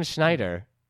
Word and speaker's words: Schneider: John Schneider. Is it? Did I say that right? – Yeah.